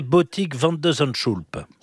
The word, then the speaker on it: Botik van de Zandschulp: Et donc là, en 8e, quel est le niveau de son adversaire, ce fameux néerlandais Botik van de Zandschulp? – Oh, mais vous prononcez très très bien.